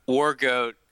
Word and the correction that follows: Wargotti: Is it Wargoat? Yeah. I think it's Wargoat. – Wargoat. – It's Italian. It's Wargotti. – Wargotti.